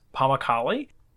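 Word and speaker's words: Pamukkale: To start, let's actually talk about the name Pammukale and how to pronounce it. So I actually went to book our local bus tickets call, and I was like, 2 tickets to Pammukale, please. And quickly, they didn't know what I was talking about. I had to write it and translate it. So it is pronounced Pammukale.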